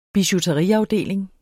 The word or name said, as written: bijouteriafdeling